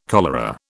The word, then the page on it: cholera: British English